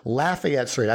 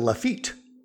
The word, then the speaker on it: Lafayette: Lafayette Street. I think I was saying Lafayette, but yes, it's Lafayette. And I've also read somewhere that some people might even pronounce it Lafitte.